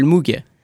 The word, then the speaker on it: Moog: C'est Jean-Michel Moog. – Moog, pardon, je l'ai mal prononcé.